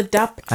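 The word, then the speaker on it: dopp kit: D-O-P. Dop. – Doc. Like, I always call it dop kit, D-O-C. – Oh, I think it's DOP. – What's the correct word? – It's called a dop kit. – I've been saying it wrong for 38 years.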